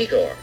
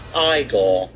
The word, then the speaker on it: Igor: You must be Igor. – No, it's pronounced Igor.